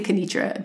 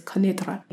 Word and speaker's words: Kenitra: I was going to be teaching in a small city called, well, I say Kenitra, but technically the word, how you say it is Kenitra.